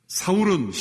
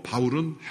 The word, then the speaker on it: 사울: 사울은 히브리식 발음이고 바울은 헬라식 발음입니다.